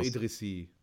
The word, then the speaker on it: Idrissi: Den Nachnamen wird man wahrscheinlich nicht so aussprechen, aber... – Idrissi? Doch, der sagt doch auch Idrissi, der Blendi, oder? – Ja.